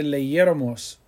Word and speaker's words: leyéramos: Era bueno que leyéramos muchos libros. Leyéramos, L E Y E R A M O S, accent on the E after the Y, leyéramos.